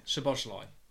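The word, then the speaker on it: Szoboszlai: Is it Schlobberschlei? Is that how you say it? – Schlobberschlei.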